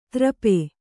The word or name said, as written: ತ್ರಪೆ